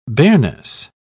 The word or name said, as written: bareness